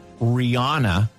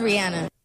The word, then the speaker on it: Rihanna: We all say it correctly here in Ireland because we pronounce Anna like Anna. Hmm. Americans obviously say Rihanna. Here's the lady herself. – It is Rihanna. – It is Rihanna. Ah.